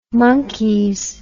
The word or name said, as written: monkees